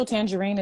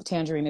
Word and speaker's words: Tanjareen: Official Tangerine is my name on Facebook and Instagram. Tangerine is not spelled like the fruit. It's spelled T-A-N-J-A-R-E-E-N like it is on the flyer that he posted.